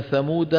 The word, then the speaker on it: ثمود: لاحظوا كأن لم يغنوا فيها ألا إن ثمود كفروا. ثمود تجدون فيها ألفا مكتوبة لا تلفظ على رواية حفص، لأن ثمود ممنوع من الصرف على رواية حفص عن عاصم.